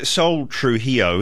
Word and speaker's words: Sol Trujillo: Can I ask, Sol Trujillo, is that how you say it? – Yeah.